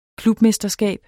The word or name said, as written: klubmesterskab